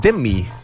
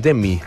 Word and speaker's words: Demme: Que ahora aprendí, se dice Demme. – ¿En serio? – Jonathan Demme, mira, así se pronuncia.